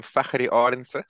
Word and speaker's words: Fakhrie Arendse: So I'm Fakhrie Arendse from Cape Town, South Africa.